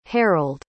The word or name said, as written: harold